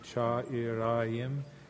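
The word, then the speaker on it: çağırayım: Cha, the C with the squiggle, is a cha. E, and again the silent G, cha irayim, mevlam seni.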